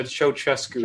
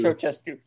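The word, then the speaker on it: Ceaușescu: Forgive me if I'm pronouncing his name wrong. Ceausescuism? – It's a Ceausescu. – Ceausescu.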